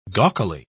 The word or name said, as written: gawkily